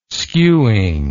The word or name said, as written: skewing